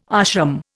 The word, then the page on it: ashram: Indian